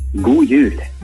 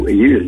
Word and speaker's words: God jul: God jul. – God jul. Did I say that right? – God jul.